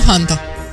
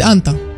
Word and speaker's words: Hanta: Nimeni nu știe exact dacă se pronunță H-ul ăla sau nu. – Da, dacă ești în engleză, ci că se pronunță. – În franceză, sigur, nu. – Da. – Eu îi spun Hanta. – Da, bineînțeles. Pentru prieteni, Hanta. Da. – Na. – Și... – pentru francezi, Anta.